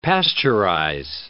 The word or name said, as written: pasteurize